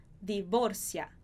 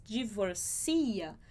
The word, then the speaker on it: divorcia: Atenção aí à pronúncia, porque a gente escreve exatamente da mesma forma. Em espanhol, a gente fala divorcia. Ella no se divorcia. Em português, divorcia. Então, é diferente aí a sílaba mais forte.